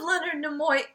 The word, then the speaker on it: Leonard Nimoy: I've read both of Leonard Nimoy.